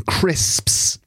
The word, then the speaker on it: crisps: That can be a difficult word for people to pronounce. – It is. I think it is. – It's like crisps and also wasps.